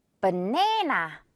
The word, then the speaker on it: banana: 한국말로는 이거 바나나긴 한데 영어 발음은 버로 시작한다고 생각하세요. 버. 그리고 네나, 네 부분에 길게 끌면서 강세 주면 되는데 이 앞부분 버 할 때 목 진동, 성대 울리도록 신경 쓰면 돼요. banana, 버, banana.